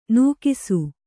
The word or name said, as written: ನೂಗಿಸು